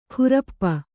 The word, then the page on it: ಖುರಪ್ಪ: khurappa